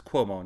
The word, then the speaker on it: Cuomo: It's Cuomo, not Kumo, right? It's Cuomo? – I can't pronounce it either. – Well, I thought it was Kumo, but... – No, it's Cuomo.